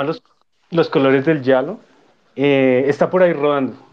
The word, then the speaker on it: giallo: El yalo, se pronuncia giallo.